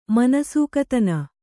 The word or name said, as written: ಮನಸೂಕತನ